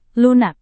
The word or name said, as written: lunak